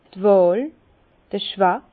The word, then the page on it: d' Wàhl, d' Choix: Bas-Rhin; Herrlisheim